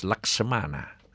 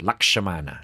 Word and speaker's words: Lakshmana: Now, his brother, younger brother, was Laksamana. It's pronounced Lakshmana.